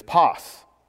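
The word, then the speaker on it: pos (πᾶς): So the word for all things is pos, okay? That's how it's pronounced, pos.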